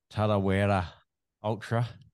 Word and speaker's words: Tarawera: And hey, good pronunciation of the word Tarawera. It's a bit of a hard one for the foreigners to grasp that local lingo.